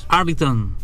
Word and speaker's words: Arlington: El Arlington Stadium, Texas, Estados Unidos.